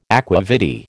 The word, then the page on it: aqua vitae: American English